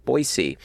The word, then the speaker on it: Boise: Thank you, for saying Boise correctly. With the influx of people who moved here and they're saying it wrong, it's nice to have someone not local acknowledging the proper pronunciation, Boise. – Boise. – It's a very very common one, not Boise, mm-hmm.